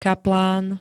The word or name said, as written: kaplán